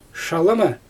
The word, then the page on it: ʃalɯmɯ: Xyzyl